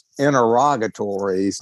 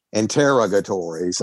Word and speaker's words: interrogatories: I do have a problem that I mean, I've watched a lot of legal television in my life, and I think the word is pronounced interrogatories, not interrogatories.